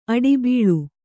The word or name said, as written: ಆಡುಬಳ್ಳಿ